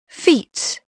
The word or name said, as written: feet